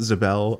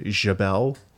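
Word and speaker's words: Xebel: X-E-B-E-L. I pronounce it Zabel. I think they in the Aquaman movie they said Jabel. I don't know.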